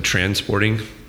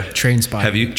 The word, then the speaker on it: Trainspotting: He's known for directing Transporting. Trainspotting, is that how you say it? Yeah. – Trainspotting.